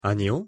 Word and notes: 아니요: Korean

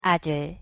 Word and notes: 아들: Korean

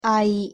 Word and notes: 아이: Korean

